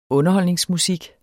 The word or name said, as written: underholdningsmusik